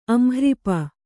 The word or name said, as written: ಅಂಹ್ರಿಪ